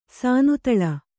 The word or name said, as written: ಸಾನುತಳ